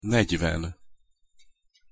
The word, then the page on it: negyven: Hungarian